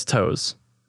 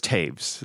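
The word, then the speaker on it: Toews: Probably my most common mispronunciation is Toes. But it's Taves. The O-E makes an A and the W makes a V.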